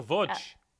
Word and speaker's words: Łódź: I'm in Łódź. – Oh, Łódź. – L-O-D-Z with the Łódź.